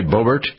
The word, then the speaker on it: Boebert: Lauren Boebert, that's how you pronounce her name, right? Boebert? – That's it, Boebert.